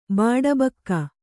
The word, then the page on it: ಬಾಡಬಾಕ: kannada